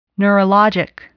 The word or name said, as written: neurologic